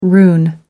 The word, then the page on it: rune: American English